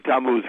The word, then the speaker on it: Tammuz: There's a chapter called the ninth of, I'm going to say this, I hope I'm saying it right, Tammuz. – Yeah, the night. Yeah, that's pretty good. The ninth of Tammuz, because it's a Hebrew month, you know.